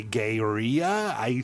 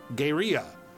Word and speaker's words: Gaerea: I just went, okay, Gayria. It's spelled G-A-E-R-E-A. It could be a completely different pronunciation.